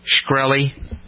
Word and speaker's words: Shkreli: Is that how you pronounce his name? Shkreli, S-H-K-R-E-L-I, Shkreli.